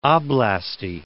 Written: oblasti

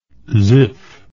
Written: Zif

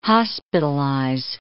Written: hospitalize

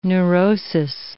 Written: neurosis

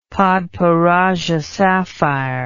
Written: padparadschahsapphire

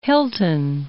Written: Hilton

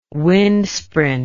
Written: windsprint

